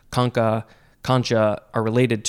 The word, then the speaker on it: concha: Next is the second form of this same word, but instead of pronouncing it concha, it is concha. Concha, like the way it's spelled. Could also be concho.